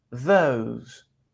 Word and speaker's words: those: Vieron que suena O, O, O. Y la última E no suena. Those, those.